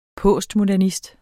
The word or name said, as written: postmodernist